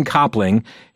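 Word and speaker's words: Copping: Pastor, is it pronounced Copling? – Yeah. – Jason Copling.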